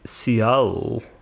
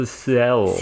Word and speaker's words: Seattle: Или, как правильно говорить, Seattle. – Seattle? – Seattle. Seattle, окей.